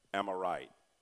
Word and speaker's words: amirite: The word Amorite. Am I right? It's spelled A-M-I-R-I-T-E.